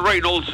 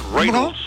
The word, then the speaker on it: Reynolds: Ryan Reynolds, note la pronunciación, Reynolds, ¿cómo? Reynolds. – Reynolds.